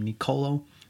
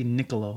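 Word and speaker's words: Nicolò: I just realized in the last couple episodes that I've been mispronouncing his name, calling him Nicolo. It's actually Niccolo, like Piccolo from Dragon Ball Z, which makes me like him even more.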